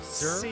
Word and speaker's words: Cyr: Sear? Sir? Yes. yeah. yeah C Y R is the spelling. C Y R.